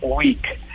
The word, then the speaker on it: Wickr: Hay uno que es muy chévere que se llama WIC, se escribe W-I-C-K-R.